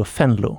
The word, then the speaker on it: Venlo: Hvordan uttaler man egentlig det vi kaller for WWW Venlo? For de heter jo Fenlo. Ja, Fei-fei-fei. Fei-fei-fei. Ja, Enkel V er jo F.